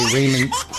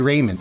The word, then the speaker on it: ramen: When I was in college, I used to say ramen. How do you say it? – Ramen. – Yeah, I used to say Raymond, and my kids laugh at me. Like, everybody loves Raymond.